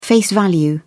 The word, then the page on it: face value: British English